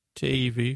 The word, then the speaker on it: Tavy: Tavy, who rhymes with gravy.